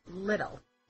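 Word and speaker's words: Liddell: We are talking about Alice in Wonderland, and now we're going to discuss Alice Pleasance Little. It rhymes with riddle.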